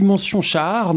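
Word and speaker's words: Mention-Schaar: Et puis, un autre film qui sort le 5 octobre avec celui de Burton, c'est Le Ciel attendra de Marie Castille, mention Char. Normalement, c'est comme ça que ça se prononce.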